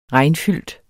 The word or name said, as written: regnfyldt